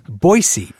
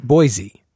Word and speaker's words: Boise: Now, with all these newcomers, one of the things they're going to have to learn is that the proper pronunciation of – your city is Boise, right? Not Boise. – That's correct. Boise is the shibboleth. Boise is what people coming in from out of town say.